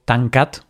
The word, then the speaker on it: Tancat: Tú sabes catalán, entonces si te digo Tancat, ¿sabes lo que significa? Qué buena pronunciación.